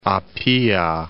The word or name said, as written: Apia